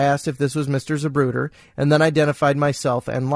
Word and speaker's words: Zapruder: Anticipating my next question, she said my friend couldn't spell the name, only pronounce it, Zapruder. I picked up the phone book and ran my finger down the Z's, and there it was, just as Patsy had pronounced it, Zapruder, Abraham.